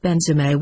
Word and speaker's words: Benzema: But he again replied Benzema with the accent on the last syllable.